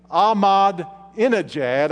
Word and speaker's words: Ahmadinejad: And if God could influence this predecessor of Ahmadinejad, I think I said that right, the Iranian president, then God can save anybody. Don't write off anyone as being too bad to be reached by the gospel. As a matter of fact, I have been praying for the present leader of Iran, Ahmadinejad. I said it right that time.